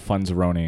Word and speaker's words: Funzaroni: Uh, and then my social media is all Funzaroni, including my website.